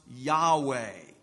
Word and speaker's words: Yahweh: When you see that in your Old Testament, this is the famous tetragrammaton, which means 4 letters, the name of God that we would refer to or we would pronounce Yahweh.